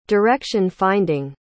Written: direction-finding

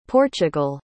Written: portugal

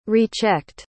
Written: rechecked

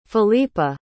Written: felipa

